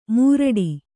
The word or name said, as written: ಮುರಡಿ